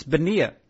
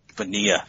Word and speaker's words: Bonilla: I believe it's Bonilla. – Bonilla.